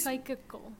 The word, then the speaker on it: psychical: No, that's the thing. It's spelled psychical. – Odd. – Let me psychical. – Psychical. Yeah, I guess it's psychical. – Like psychic.